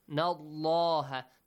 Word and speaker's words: الله: Nadrin, fa in, nallaha. The letter lam in the word Allah. has to be pronounced with a heavy sound, with an ah sound. فَإِنَّ اللَّهَ why? Because there is a fatha sound before.